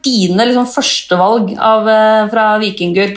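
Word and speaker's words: Víkingur: Eh, men la meg høre deg si hva, hvordan skal det lagnavnet deres egentlig uttales? – Vortjingur. – Ja, ikke sant? Men vikingur er også fint.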